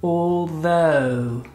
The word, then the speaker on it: although: So, the first one to kick off is a tricky one to pronounce. It is although. Although. Repeat it with me. Come on, let's spend some time, let's pronounce it correctly although. Look at my tongue, although. No, not although, though.